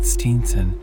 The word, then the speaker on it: Stevenson: My great-grandfather wrote the name Stevenson but pronounced it Steenson, after the fashion of the immortal minstrel in Ray Gauntlet, and this elision of a medial consonant appears a Gaelic process,